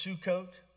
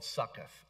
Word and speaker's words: Sukkot: So he said to the men of Sukkot." Did you know that's pronounced Sukkot? Because nobody wants to be from a town called Succoth, okay? You know what I'm saying? Because that would just Succoth.